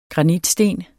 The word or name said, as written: granitsten